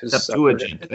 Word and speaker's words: Septuagint: Septuagint.